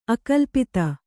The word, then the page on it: ಅಕಲ್ಪಿತ: akalpita